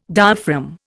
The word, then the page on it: diaphragm: Indian